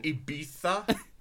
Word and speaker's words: Ibiza: At Ibiza. I believe that's how it's pronounced.